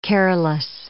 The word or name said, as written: careless